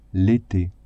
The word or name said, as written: ete